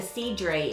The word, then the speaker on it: cidre: That's not the cidre, is it? Is it spelled C-I-D-R-E? – Cider? Yeah, it's the cider. – It really is pronounced cidre. For that brand.